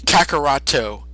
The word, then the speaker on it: Kakarot: It's Vegeta and Kakaroto, and typically the guy's name is Kakarot, but the way the Japanese pronounce it, like, you know, Kakaroto, just like the hard, like, echoing syllable of the T, he just kind of went off that.